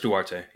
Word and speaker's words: Duarte: I don't know if I'm pronouncing his name right. The dude, Chris Duarte. Yep.